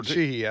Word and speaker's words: Sheehy: Sheehy, I think it is.